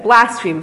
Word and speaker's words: Blaspheme: Blaspheme? Am I saying it right? – Yeah. – Oh, so I said it wrong one time, so my mother told me the other way. Blaspheme.